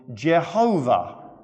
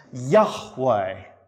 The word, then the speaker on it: Yahweh: Hence, in the King James, you will find he is called Jehovah. That's definitely the wrong set of vowels. Probably, most likely, the name was supposed to be pronounced Yahweh.